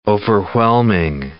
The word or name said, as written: overwhelming